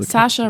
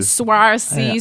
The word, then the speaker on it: Saoirse: Ja, Saoirse Ronan. – Verstehe. Kann sie sich aber auch einfach S-A-S-H-A schreiben, also meine Güte. – Swarcy, Srissen, Rissena.